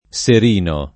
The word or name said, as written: Serino